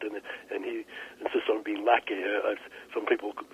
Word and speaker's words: Lache: Lackey Searsill, did I pronounce his name right? – It's Lackey Searsill.